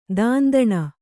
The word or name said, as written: ದಂಧನೆ